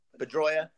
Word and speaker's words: Pedroia: How do you say his last name? Pedroia.